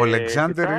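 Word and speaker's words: Alexander: Ανακοινώθηκε ο Αλεξάντερ Ζουμκόφ, έτσι, μάλιστα. – Είναι και επίσημα πλέον παίχτης. – Ο Αλεξάνδερ είναι η προφορά, Αλέξανδρος είναι δηλαδή.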